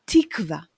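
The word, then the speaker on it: תקווה: But the actual word and pronunciation should be tikva. You already know that in Hebrew we put the stress at the end of the word or right before the end of the word.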